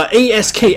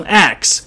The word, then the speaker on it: ask: 注意啊，咱们一哥把 a s k ask 读成 x，这是很多黑人常犯的一个错误，也有一些是从小养成的习惯。